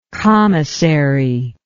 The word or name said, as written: commissaryship